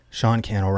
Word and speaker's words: Cannell: Sean Cannell rhymes with YouTube channel on social media.